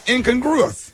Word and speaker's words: incongruous: You said it fast and you said it wrong. – Yeah, you said incongruous. – The word of the week is incongruous. – That's right. – Yeah, that's right.